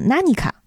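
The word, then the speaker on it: ナニカ: 然后这个里人格拿尼加的日文发音呢是 nanika，翻译过来呢是什么东西？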